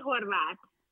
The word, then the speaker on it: Horvath: My name is Kitti Horvath. – I love it. – It's really difficult to pronounce because it's a Hungarian name.